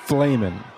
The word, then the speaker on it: Flehmen: I'm guessing P-F-L-A-M-M-E-N. – No. – Damn. – What the... – Flamin'. F-L-E-H-M-E-N.